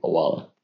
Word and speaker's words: Owala: I'm a big Owala guy as well. You're totally right. – Owala. That's how you pronounce it.